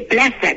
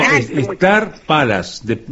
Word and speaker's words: Star Palace: Ahí está, se dice, se escribe Palace y se dice Plaza, ¿no? – No, Star Palace. Star de estrella y Palace de palacio, Star Palace.